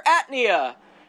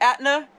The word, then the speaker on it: Aetna: I have a cure, Atnia. It's me, Atna. How do you say that? Atna, Aetna, Edna.